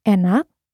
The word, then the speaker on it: enak: Yeah, but there are a number of exceptions, like enak, which means delicious. In this word, the second syllable is stressed, but the first E is still pronounced as E.